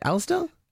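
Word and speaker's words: Alastor: Alistair. – I just go Alistair, I guess.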